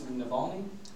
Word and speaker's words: Navalny: So, one of the opposition leaders recently, correct me if I miss the pronunciation, Alex Navalny? – Close enough, good.